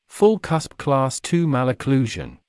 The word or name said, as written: full cusp Class II malocclusion